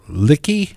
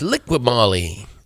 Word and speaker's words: Liqui Moly: Is it Liqui Moly Junior Cup? The Liqui Licky? – That's Liqui Moly. Liqui Moly.